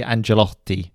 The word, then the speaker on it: Ancelotti: Uh, it's actually so it would be Ancelotti.